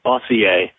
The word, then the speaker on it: Bossier: This is Bossier City, Louisiana. I noticed that I think Chris had said Bossier. I know we're French here in Louisiana, but it is pronounced Bossier.